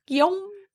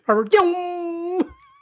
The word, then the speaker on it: Guillaume: Guillaume. – Don't do that. It's not a sound effect. He's not zooming by. Robert Guillaume. He's not a race car.